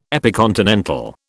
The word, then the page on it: epicontinental: British English